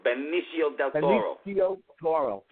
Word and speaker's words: Benicio del Toro: Benicio del Toro.